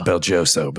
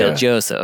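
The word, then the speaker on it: BelGioioso: It's pronounced Belgioso, but yeah. – Belgioso, okay.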